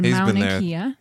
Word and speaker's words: Mauna Kea: He said Mauna Kea.